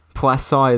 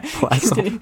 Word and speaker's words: poisson: But, poisson is a pun on poison. – Because it sounds like poison. – Yeah. It's spelled like poison, at least. It doesn't sound like it.